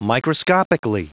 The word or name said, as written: microscopically